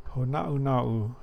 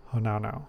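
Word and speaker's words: Hōnaunau: So it's Honaunau is probably the correct way to say it, right? But I call it Honaunau.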